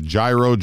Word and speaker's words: gyro: Gyro, right? – Gyro. It's almost without the G. It's like a G-R or G-Y sound.